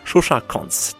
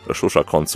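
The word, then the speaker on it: Koncz Zsuzsa: Shusha Konz, die Schreibweise, die erfordert Konzentration, will sie gar nicht genau buchstabieren, aber Shusha Konz, so kann man es aussprechen.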